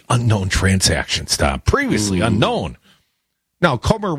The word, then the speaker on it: Comer: Comer, rhymes with Gomer,